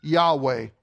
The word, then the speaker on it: Yahweh: So when he says this, "I am who I am," he's actually saying Yahweh, YHWH. And the reason why it's like that is because they didn't have any vowels at that time, and a lot of Jews didn't even dare say his name.